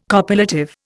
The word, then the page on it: copulative: Indian